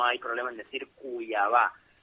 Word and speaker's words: Cuiabá: Pues estamos muy bien, ansiosos de conocer Cuiabá.